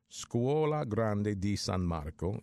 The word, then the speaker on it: Scuola Grande di San Marco: But there was this one in the Museum of Scuola Grandi da San Marco. I'm sorry, let me pronounce that again. I mispronounced it. Scuola Grande di San Marco in Venice, Venezia, is hosting an exhibit of the Shroud of Turin that began last month.